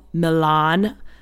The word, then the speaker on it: Millon: Should be no shock to anyone, because if you look at his Instagram, which is Antonio Milan, but it's spelled M-I-L-L-O-N, like million without the I, he's just got pictures of himself left and right.